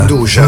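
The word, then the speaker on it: 'nduja: Hai sentito la pronuncia? – Undugia. – Undugia, che è la pronuncia giusta, originale, ufficiale.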